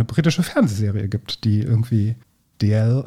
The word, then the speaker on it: Dalziel: June DL Almeida und das DL in der Mitte wird geschrieben D-A-L-Z-I-E-L. – E Nochmal. D-A-L? – Z-I-E-L.